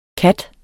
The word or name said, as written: kat